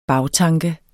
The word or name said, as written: bagtanke